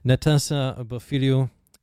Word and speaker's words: Natassa Bofiliou: A Greek artist, I'm going to give my best shot at pronouncing the name, Natassa Bofiliou, a track yes. – called... Ah, thank you. Always nice to get that validation.